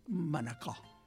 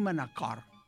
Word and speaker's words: Manacor: S'ha de dir així. – Ja ho tens. – Sí. Un valencià podria dir Manacor, perquè és legítim perquè és per un valencià sí, pronunciar la R final. Però la O ha de ser sempre oberta.